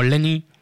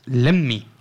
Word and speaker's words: Lemmy: Un homenaje un poco tardío a el que por aquí conocíamos como Lenny de Motorhead, pero que entiendo que debido a estas dos M's siempre ha estado mal pronunciado, como tantos otros nombres. Es Lemmy o algo así. – Lemmy.